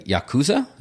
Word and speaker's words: Yakuza: By the Yakuza. Is that how you say the Japanese? – Yeah, I think mafia? You're saying it right.